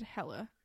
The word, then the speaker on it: Helle: And I looked up the pronunciation of her name because it's H-E-L-L-E. And everything I saw said Helle.